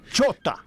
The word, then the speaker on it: Chota: Chota. – Chota. – Chota. – Chota.